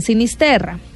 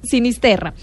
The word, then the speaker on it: Sinisterra: Y entonces aquí la pregunta de Fran Sinisterra. Hoy lo dije bien porque ayer Frank me escribió que siempre decía mal su apellido. Frank Sinisterra.